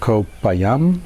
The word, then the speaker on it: Koh Phayam: By the way, we are sitting on an island in southern Thailand by the name of Koh Phayam. Is that how it's pronounced? – Yep.